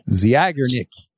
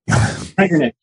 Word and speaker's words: Zeigarnik: Zeigarnik. – Zeigarnik, yeah.